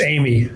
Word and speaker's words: Eighmy: It's, it's Amy.